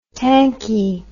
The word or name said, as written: tankie